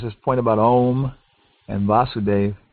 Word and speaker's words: Vasudeva: But Vasudev with a short A is the father of Krishna, And Vasudeva with a long A is Krishna.